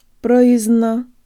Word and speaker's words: Произношение: Произношение.